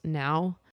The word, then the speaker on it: PNAU: So the song's called A-E-I-O-U, and I think the DJ is pronounced Now, but it's like P-N-A-U.